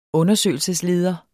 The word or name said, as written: undersøgelsesleder